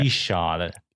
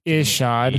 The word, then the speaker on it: Ishod: Ishad. – Ishad.